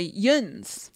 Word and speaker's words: younz: In Western Pennsylvania, where I'm from, you say yuns. – Yuns. – Whoa. – Yuns. – How do you spell that? – Apostrophe. – Y-O-U-N-Z, but then there's another version of it, yinz, which is Y-I-N-Z.